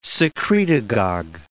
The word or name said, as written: secretagogue